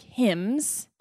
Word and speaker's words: hymns: Is it hymns? How do you say it? – Hymns. – It's hymns. – Did you really M? – Yeah, hymns.